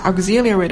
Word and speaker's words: auxiliary: 这里注意一下 auxiliary 的发音，重音在 x 这个字母上，而这个 x 发音 gz，它会产生拆分，并分别和前后的元音更紧密的连接。